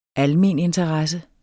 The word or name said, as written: almeninteresse